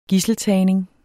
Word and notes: gidseltagning: [ -ˌtæˀjneŋ ]; [ -ˌtæːjneŋ ]

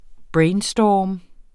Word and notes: brainstorm: ˈbɹεjnˌsdɒːm; ˈbɹεjnˌsdɒˀm

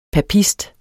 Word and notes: papist: paˈpisd

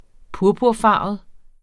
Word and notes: purpurfarvet: -ˌfɑˀvəð; -ˌfɑːvəð

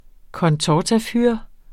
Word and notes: contortafyr: kʌnˈtɒtaˌfyɐ̯; kʌnˈtɒtaˌfyɐ̯ˀ

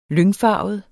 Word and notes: lyngfarvet: [ -ˌfɑˀvəð ]; [ -ˌfɑːvəð ]

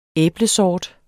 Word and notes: æblesort: [ -ˌsɒːd ]; [ -ˌsɒˀd ]

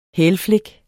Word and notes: hælflik: [ ˈhεːl- ]

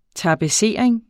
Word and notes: tapetsering: tɑbəˈseˀɐ̯eŋ; tabə-

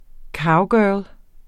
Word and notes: cowgirl: ˈkɑwˌgœːl; ˈkɒw-

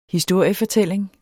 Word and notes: historiefortælling: dansk

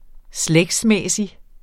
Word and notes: slægtsmæssig: ˈslεgdsˌmεˀsi; -ˌmεsi

